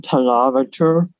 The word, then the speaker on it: tonometer: And the name of that device is the tonometer, rhymes with thermometer.